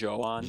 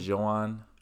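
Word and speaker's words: Jhoan: I think the J is silent, or not silent, but soft. I think it's like Johan. – Johan.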